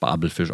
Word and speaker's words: Babelfisch: Und seitdem, du kennst den Bubblefisch, oder? Kennst du den Bubblefisch? Natürlich kenne ich den Bubblefisch. – Natürlich, danke. – Aber eigentlich wird der korrekt Babelfisch ausgesprochen. Ne? – Das ist richtig, das stimmt.